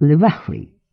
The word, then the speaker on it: lavatory: Произносится как «lavatory» с ударением на последнем слоге.